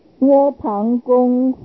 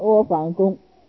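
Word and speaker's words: 阿房宫: 刚哥一篇文章是窝旁公户，这个房子要读旁，窝旁公不要读成窝房公，因为这个旁字是大的意思，很大很大的宫殿，所以取名叫做窝旁。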